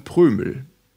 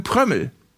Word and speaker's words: Prömel: Er wird ja mit einem Ö und einem M geschrieben, da sagt man dann Prömel. Komischerweise gibt es ein Agreement, ein unausgesprochenes zwischen vielen, vielen Kommentatoren, die Spiele von Union Berlin kommentieren, und die sagen Prömel. Obwohl er doch mit einem Ö und einem M geschrieben wird, sagen die Prömel.